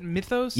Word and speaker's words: mythos: Is that how you pronounce it? Mythos? – Yeah, exactly. – That would be a whole about all the flavors. – It's pronounced mythos.